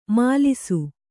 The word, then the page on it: ಮೆಲಿಸು: melisu